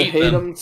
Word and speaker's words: Hadum: I don't know how to say this. Haddam, I guess? – Yeah, Haddam.